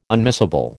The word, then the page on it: unmissable: American English